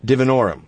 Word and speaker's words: divinorum: Seemed to have trouble pronouncing the species name of Salvia divinorum. – Yes. – It's simple. The is that the I or an the I in the species name is short for both. The stress is on the third syllable.